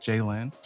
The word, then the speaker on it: Jaylin: and they could not pronounce my English name, which is like, it's just Jaylin. – Jaylin. – Yeah. So they would call me like Chelly.